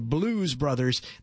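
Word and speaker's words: Blooze Brothers: Now, the band is going to be called the Blues Brothers. They spell it a little different. B-L-O-O-Z-E. Kind of like booze, I think.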